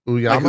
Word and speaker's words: Uyama: Yeah, I don't know how to say it. Mike Uyama? – Uyama. Mike Uyama, yeah.